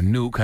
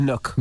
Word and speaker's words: Nook: If anything, you should be suing Kindle, Nook, or Nuke, however you pronounce Nook. – It. – It's a Nook.